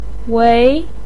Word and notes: wei2: pinyin

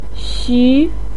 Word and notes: xu2: pinyin

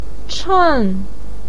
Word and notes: cheng4: pinyin

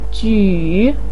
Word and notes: ju3: pinyin

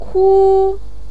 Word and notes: ku1: pinyin

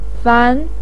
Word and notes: fan2: pinyin

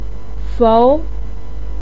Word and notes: fou2: pinyin

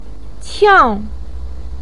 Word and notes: qiang4: pinyin

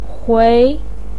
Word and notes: hui2: pinyin

